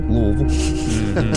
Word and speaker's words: Louisville: No, I said Louisville. I always say Louisville. – Louisville. – Louisville. – Two syllables.